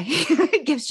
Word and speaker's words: GIF: It's gift without the T on the end.